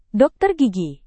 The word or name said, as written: dokter gigi